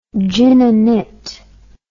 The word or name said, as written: gin and it